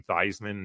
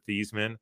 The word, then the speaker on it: Theismann: And for those that don't know, he was the one that told Joe Theismann to pronounce his last name Theismann instead of Theismann, so he can make a run for the Heisman.